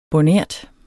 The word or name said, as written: bornert